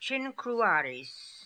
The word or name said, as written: chinkrooaries